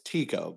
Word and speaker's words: Titko: It's like Cup of Tea Company. So it's Titko.